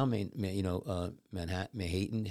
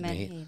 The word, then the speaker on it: menhaden: Manhattan. How do you pronounce it? – Menhaden.